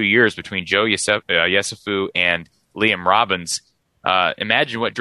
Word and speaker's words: Yesufu: By the way, Joe Yusefu from or am I pronouncing that right? Yesufu. There you go.